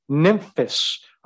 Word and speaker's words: Nymphas: And Nymphas, apparently the way you pronounce a PH is like an F, Nymphas, and the church that is in his house.